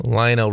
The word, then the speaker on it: Lionel: Lionel Richie. That's how you pronounce it.